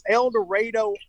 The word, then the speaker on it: El Dorado: It's spelled El Dorado, but I went online to promote the event and got crucified because I didn't pronounce it correctly. But it's El Dorado, Arkansas, and we're getting ready for a show here tonight.